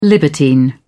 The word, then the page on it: libertine: British English